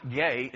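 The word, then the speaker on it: ge (γῆ): It's the Greek word, and it's pronounced ge. It's spelled G-E.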